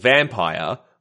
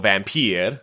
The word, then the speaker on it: Vampyr: And so they're moving into an action RPG called Vampyr, or Vampyr, or Wampyr, depending on how Latin you want to get when you pronounce it. – Let's get all Latin here. Wampyr.